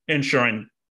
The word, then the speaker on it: Inisherin: Inisherin.